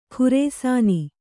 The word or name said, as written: ಖುರೇಸಾನಿ